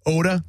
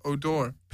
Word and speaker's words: Odor: Oh, Oda? The guy's last name's Oda? – No, it's Odor. – Pronounced Odor? – Yeah.